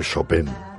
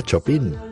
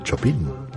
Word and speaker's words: Chopin: Que no se dice Chopin. Que el señor se llamaba Chopin. Tal como suena en español, Chopin. Que para eso era polaco, para llamarse Chopin.